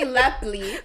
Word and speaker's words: Lepley: She said Lapel, and his name is clearly Lepley.